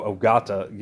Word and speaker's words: Ogata: Like, I know Ogata, you know, is correct, but or Hideto, you know, I'm not too sure.